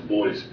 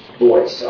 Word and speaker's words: Boice: On Skype here, and the pronunciation is Boyce, B-O-I-C-E. – Boyce, all right, so I apologize, sir.